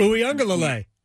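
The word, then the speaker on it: Uiagalelei: Uyunglele.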